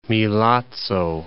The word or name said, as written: Milazzo